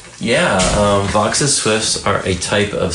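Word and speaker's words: Vaux: I don't know much about his background, but it's some people say Vo's swift, but it's actually pronounced Vox's because that's the way he pronounced his name.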